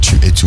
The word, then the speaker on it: Tu et Tu: That was Tu et Tu. Now, I feel pressure because you're French. Did I say that right? – Perfect. Tu et Tu.